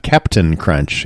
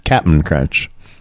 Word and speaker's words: Cap'n Crunch: It's not Captain Crunch, it's Cap'n Crunch.